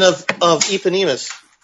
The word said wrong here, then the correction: eponymous: You mean eponymous?